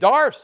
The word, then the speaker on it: Doris: D-A-R-C-Y? I've never heard that name. You've got two people in your family named Dars. Oh, yeah. Well, how do you spell it? D-O-R-I-S. Oh, Doris. Now, see, where I came from is not Cooper either.